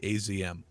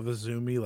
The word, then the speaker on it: Azumi: Well, it's funny because there's a video of Azumi like yelling at people. You say it, Azumi.